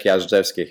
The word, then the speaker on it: Jazdzewski: It's Leszek Jazdzewski here, but please don't focus on this one.